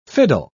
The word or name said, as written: fiddle